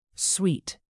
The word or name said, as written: suite